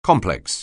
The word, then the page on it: complex: anglais